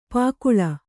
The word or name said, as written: ಪಕಳಿ